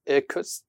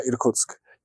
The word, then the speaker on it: Irkutsk: And he was like, oh, I've got a place you can stay at in the next big city, which was, I think, Irkutsk. Did I pronounce that right? – No, but move on. – Okay. How do I pronounce it? – Irkutsk.